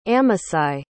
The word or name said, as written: amasai